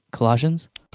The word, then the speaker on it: Colossians: Is it Colossians? – Colossians.